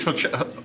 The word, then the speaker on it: horchata: Horchata.